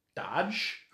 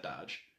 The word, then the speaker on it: Doge: We get Moody, Lupin, Nymphadora Tonks, Kingsley Shacklebolt, Alphaeus Dodge, I'm going to pronounce that Dodge, D-O-G-E,